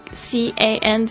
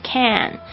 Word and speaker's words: can: 然后大家注意一下这个 C A N 这个声音呢，其实在很自然的英语里面呢，它是不会念成 can，它是念成 can。肯这样的声音，因为很快，所以就会变成 you can 啊，you can place the baggage。